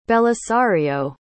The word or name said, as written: belisario